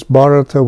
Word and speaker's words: Bharata: Notice this is Bharata with a short A in this text here,